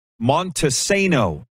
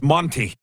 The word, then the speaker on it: Montesano: He says, pronounced Montesano or just Monty.